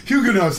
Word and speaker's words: Huguenots: Huguenots. – The Huguenots. – Huguenots, that's how you say it. – Actually, we were Huguenots. – Huguenot. No, over here they say Huguenots.